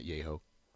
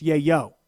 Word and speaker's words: yayo: Yayo, not yayho. – Oh, really? There's no H in there? – No. – It's not even a pronunciated J? – No. – Yeah, okay, so it's not yay-ho. – Yeah. – It's yay-yo.